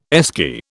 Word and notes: Esky: British English

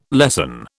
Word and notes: lesson: British English

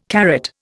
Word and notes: carrot: Indian

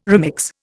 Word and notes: remix: Indian

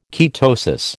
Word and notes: ketosis: American English

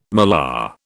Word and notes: malar: British English

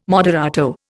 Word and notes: moderato: Indian

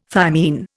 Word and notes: thymine: Indian

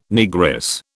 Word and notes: Negress: British English

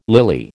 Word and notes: lily: American English

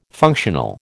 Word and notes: functional: American English

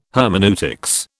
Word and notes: hermeneutics: British English

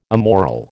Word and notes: immoral: American English

